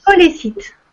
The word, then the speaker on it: scolécite: Scolécite, Comme ça se prononce.